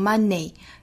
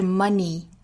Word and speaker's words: money: 我们再继续往后看，有 money, money 这个词尾部的元音哈是一个紧元音 E, 不要读成 money, 应该是 money, money, 是和长音 E 的发音方式是一样的 ,money, money, Even your health, health, 中间也包含舌侧音也要注意舌尖去够一下上齿音 health, health, 不能发成 health, even your health, even your health, your knowledge can never be taken from you.